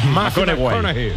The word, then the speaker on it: McConaughey: Matthew McConaughey. – Sí, sí, sí, sí. – Hay que ir corrigiendo la pronunciación. – McConaughey.